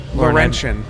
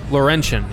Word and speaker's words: Laurentian: Laurentian. – Laurentian, yes.